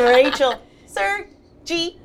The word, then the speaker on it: Rachel Sergi: And Rachel Sergi.